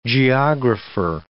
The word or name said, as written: geographer